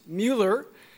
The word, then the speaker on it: Müller: So in German, which is Mueller's native language, it is spelled M-U-L-L-E-R, but there are two dots over the U, which is known as an umlaut, and so it makes it a long U, so it's pronounced Mueller.